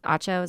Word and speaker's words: Acho: That's Acho, is that how you say it? – Yeah, I hate him, but continue.